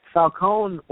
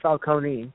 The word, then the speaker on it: Falcone: I would follow him around to learn his secrets, because that man, you know, Falcone or Falcone, depending on your pronunciation, had it right.